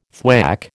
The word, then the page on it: thwack: American English